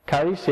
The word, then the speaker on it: charis: Charis, yeah, which is translated as grace.